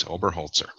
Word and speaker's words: Oberholzer: Is it Holzer. – Headwoodsman in German. Yes, Oberholzer.